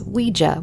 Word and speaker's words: Ouija: It is Ouija, which is the proper pronunciation, but I grew up saying Ouija like most of everybody.